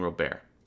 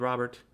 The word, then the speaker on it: Robert: and I keep saying Robert, I'm sorry, Luis Robert. I know it's spelled Robert, it's pronounced Robert, but when I first heard his name years ago, they kept saying Robert, and it's just stuck in my mind.